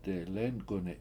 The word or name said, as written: delEnk7uni7